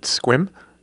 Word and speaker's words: SQIM: You pronounce it SQIM? – Yeah, SQIM.